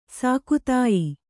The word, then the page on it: ಸಾಕುತಾಯಿ: sāku tāyi